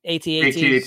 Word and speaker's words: AT-AT: It's at rhymes with bat. Some people say at. I say AT-AT.